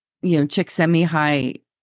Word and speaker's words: Csikszentmihalyi: And so Mihaly's last name looks quite difficult to pronounce, but in an audio I listened, Mihaly actually pronounced how to say his name, and it's Csikszentmihalyi. Mihaly Csikszentmihalyi. And so he's written many books, including Flow and Creativity.